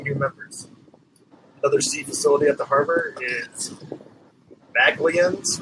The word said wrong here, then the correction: Magellan's: Restaurant. – No, Magellan's Restaurant. I tried hard. – I've butchered that.